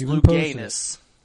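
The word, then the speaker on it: Louganis: There's one famous swimmer that turned 61 today, Greg Lucanus. That today is his birthday. It is. He's 61 today. – That's Luganus. It's Luganus, Mark.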